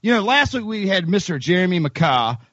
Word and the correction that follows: McCaa: McCaw, M C C I don't know, M C C A A. McKay though, pronounced McKay. McKay, that's right, McKay.